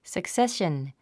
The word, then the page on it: succession: səkséʃən; サクセション